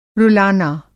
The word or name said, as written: रुलाना